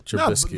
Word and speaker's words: Trubisky: Trubisky.